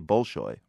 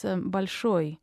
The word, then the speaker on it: Bolshoi: Now, most people, like myself, say Bolshoi, wrong? – In Russian, it's stressed on the last syllable, so it's Bolshoi. In that sense, you would anglicize it as Bolshoi.